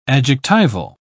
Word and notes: adjectival: British English

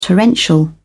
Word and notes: torrential: British English

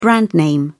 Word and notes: brand name: British English

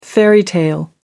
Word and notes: fairy tale: American English

